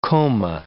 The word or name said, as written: coma